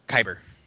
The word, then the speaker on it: Kyber: I wanted to know more about the, Kyber crystals? How's it pronounced? – Kyber.